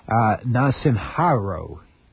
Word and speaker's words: Narasimha Rao: Narasimha, I'm sorry, there's a whole pronunciation guide over here, okay, Narasimha Rao, said there are no soft options left.